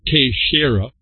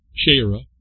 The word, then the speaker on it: Teixeira: As I said, it was Portuguese, and it's Teixeira, Teixeira, and how I'm pronouncing it. I want it Teixeira, nice and simple for an American in Texas, I suppose.